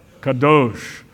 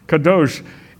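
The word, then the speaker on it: Kadosh (קדוש): How do you say holy in Hebrew? Kadosh. Kadosh.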